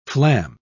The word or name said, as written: flam